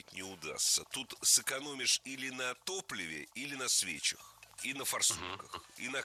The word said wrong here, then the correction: свечах: На свечах, неверно, правильно, на свечах. Даже если речь идет об автомобильных свечах. Особенность склонения этого слова заключается в том, что ударение всегда падает на окончание. И в единственном числе, и во множественном.